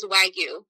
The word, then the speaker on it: Wagyu: Is it just Wagyu or Wagyu? Hard G or no G? – So you'll hear it a lot of different places. Mm-hmm. the way it technically is pronounced is Wagyu.